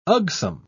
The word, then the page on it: ugsome: British English